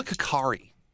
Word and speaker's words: kukri: It's called a kukri. – It's a kukri, trust me. – Kukri. There's no A in it.